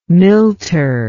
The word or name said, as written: milter